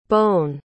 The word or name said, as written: Bown